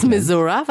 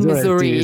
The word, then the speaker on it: Missouri: Missouri? Was soll das sein? Oder meinst du Missouri? – Ist die coole Aussprache von Missouri.